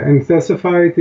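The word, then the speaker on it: enthesophyte: What is an entesifite, and am I pronouncing it correctly? – Yeah, entesifite is a, you can say, bone spur, basically.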